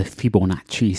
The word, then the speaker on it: Fibonacci: A lot of sort of paintings of birds, a lot of bird sketching, a lot of examinations of the Fibonacci sequence. That is the correct way to pronounce that word, by the way.